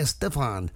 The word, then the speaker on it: Estefan: Happy 69 to singer Gloria Estefan. As some say Estefan, I always pronounce it as Stefan. Anyway, everybody says Gloria Estefan. Gloria Estefan.